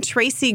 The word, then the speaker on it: Tracey: Or you can just Google my name, Tracy Gritz, T-R-A-C-E-Y, Gritz, G-R-I-T-Z, like the food here in the South.